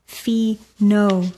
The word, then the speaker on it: Fino: Fino, pronounced Fee-no,